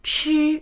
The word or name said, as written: chi1